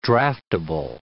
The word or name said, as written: draftable